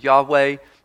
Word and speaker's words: Yahweh: And that word Lord, as I mentioned last week, is the Hebrew word that can be pronounced Yahweh.